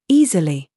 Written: ZOOS

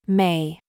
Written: mum

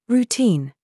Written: RAW

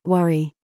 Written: STRUT

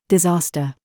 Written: PALM

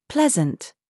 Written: ZOOS

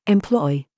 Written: CHOICE

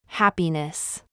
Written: haha

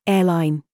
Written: SQUARE

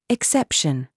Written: SAUCE

